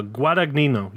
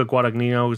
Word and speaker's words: Guadagnino: Uh, Guadagnino, yeah, Luca Guadagnino.